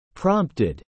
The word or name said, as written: prompted